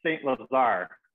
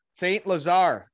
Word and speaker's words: St. Lazare: It's a hard one to pronounce, but it's St. Lazare. – St. Lazare. Okay. – Yeah, there we. Now we got it.